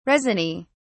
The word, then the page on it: resiny: British English